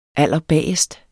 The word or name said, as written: allerbagest